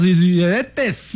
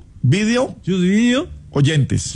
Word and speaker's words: videoyentes: De las asas, salude a los y pronuncie bien, por favor, YouTube video oyentes. – Ah, yo digo los videntes. – No. A ver, YouTube video oyentes.